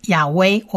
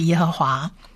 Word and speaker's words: 耶和华: 它就是亚威，我们就直接把它念成亚威。那当然有一些人在翻译上会把它翻成亚威这个词，但是我们比较倾向于把它念成是耶和华，按照经文来念。